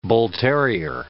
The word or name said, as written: bull terrier